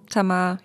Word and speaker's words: Tamar: Tamar, wie wird es ausgesprochen? – Tamar, ja.